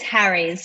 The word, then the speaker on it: Harries: It's Harries. So it's like Harry, a plural, but it's Harries.